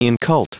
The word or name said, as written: incult